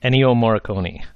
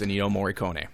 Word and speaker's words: Ennio Morricone: Ennio Morricone, I believe is how you pronounce it. – Yeah, that's how I was going to say it. I was going to let you say it, because I'm not sure how to say it. But I believe it's Ennio Morricone.